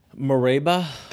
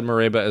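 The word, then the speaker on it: Mereba: No, I'm pretty sure you said it right. I would have said Mareba as well.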